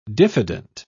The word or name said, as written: diffident